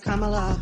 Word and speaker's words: Kamala: It's pronounced Kamala. It rhymes with Kamala.